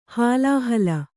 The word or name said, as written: ಹಾಲಹಲ